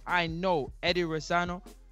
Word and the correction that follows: Reynoso: saying that wrong, Eddie Reynoso. Reynoso.